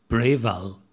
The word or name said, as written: Braeval